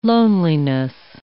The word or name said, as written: loneliness